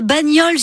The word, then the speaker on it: Bagnols: Vous avez un Bagnols sur 16, mais on ne prononce pas le S.